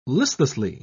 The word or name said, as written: listlessly